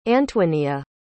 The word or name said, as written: antoinia